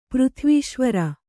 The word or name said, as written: ಪೃಥ್ವೀಶ್ವರ